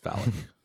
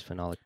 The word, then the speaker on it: phenolic: It's like a phenolic. – Yeah. – Nameplate. – It's pronounced phallic.